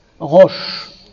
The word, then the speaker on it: Rosh: Que vous prononcez roche.